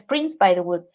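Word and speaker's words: Prince-Bythewood: es compuesto y siempre lo digo mal es en este momento les digo, Gina Prince by the Wood. Es que su nombre es Prince by the Wood.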